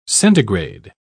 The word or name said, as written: centigrade